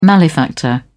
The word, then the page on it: malefactor: British English